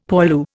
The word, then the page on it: poilu: Indian